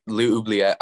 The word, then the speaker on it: L'Oubliette: And then seeing the like amazing stuff that was in like fresh film, like L'Oubliette, I butchered that, but the like black and white animation, like that blew my mind away. – The Oubliette, yeah, you did say it correctly.